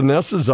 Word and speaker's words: Njos: NJOS is pronounced Ness. Ness.